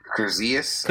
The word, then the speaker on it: Kurzius: So the early beginnings of the company, it was founded by Ben Chestnut, Mark Armstrong, and Dan Kurzuzzi. Am I pronouncing that right? I might be pronouncing it. – Kurzius. – Okay, Kurzius.